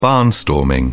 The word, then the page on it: barnstorming: British English